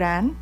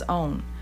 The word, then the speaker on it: on: whether it rhymes with yawn, Don, Donald Trump, or own, like it belongs to me. So up north in Chicago, I got to put my coat on. In St. Louis and in the Midwest, we say on, it rhymes with yawn. That is really getting on my nerves. In Memphis and further south, it becomes own.